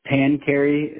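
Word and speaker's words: Pancheri: Pancarry.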